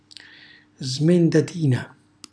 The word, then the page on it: smendatina: Calabrese